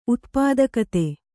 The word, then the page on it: ಉತ್ಪಾದಕತೆ: utpādakate